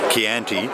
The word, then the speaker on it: Chianti: How do you say it? – Chianti. – Chianti, I love it.